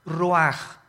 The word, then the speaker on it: ruach: El Ruach, que se escribe R-U-A-C-H, Ruach. En el hebreo, y se pronuncia igual, ruaj.